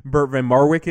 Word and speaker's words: Bert van Marwijk: And so in terms of the fact that now there's a Dutch manager, and before we get to Bert van Marwijk, am I pronouncing that correctly? – Yeah, that's as good as my Dutch is.